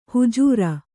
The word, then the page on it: ಹುಜೂರ: hujūra